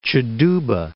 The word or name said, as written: Cheduba